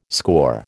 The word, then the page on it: scaur: American English